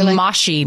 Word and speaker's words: Moshi: Oh, those mushy monsters. They're actually moshy like monsters. Bubbles. We're supposed to, we should know better to say it correctly. Moshy monsters.